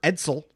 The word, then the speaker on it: Ejdsell: Uh, Swedish center Victor Ejdsell, just like the car, pronounced like the car and a fourth round draft pick.